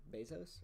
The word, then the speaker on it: Bezos: Bezos, right? – Yeah.